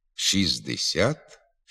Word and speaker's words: шістдесят: Пишемо «шістнадцять», «шістдесят», «шістсот», але буква «Т» у них не вимовляється. «Шістнадцять», «шістдесят», «шістсот».